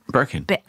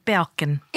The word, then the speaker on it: Birkin: I like how did you pronounce Birkin? – Birkin. – I thought you said Balkin. – No. No. I did not say Birkin. I said Birkin.